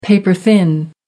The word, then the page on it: paper thin: American English